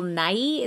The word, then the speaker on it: Nighy: Okay, so for the cast, they have Bill Nighy. Is that how you say his name? – Yes.